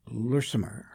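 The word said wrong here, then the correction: Lurtsema: Am I pronouncing that Lertzema. – Right? Lertzema. – Yes.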